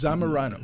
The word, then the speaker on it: Zamorano: My returning guest tonight, in the studio no less, ornithologist, professor of natural resources management at Zamorano. Is that how you pronounce it? Is that the correct thing? That's close enough. It's Zamorano.